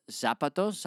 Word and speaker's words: zapatos: So we're starting with the zapatos.